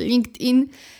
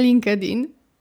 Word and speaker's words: LinkedIn: Także LinkedIn, LinkedIn, LinkedIn, nie LinkedIn.